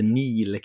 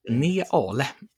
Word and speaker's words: Neale: Och, och det, det, ska tydligen varit, vara riktigt bra gjort för att regissören till de här klippen är Mark Nail eh, med reservation för att jag uttalar det fel. Eller Neil kanske. Neil låter mer. Ja, Neale. Ja.